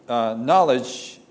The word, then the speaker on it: knowledge: And wisdom, knowledge, is gnosis. In English, knowledge is K-N-O-W-L-E-D-E-G, but you do not pronounce K, right?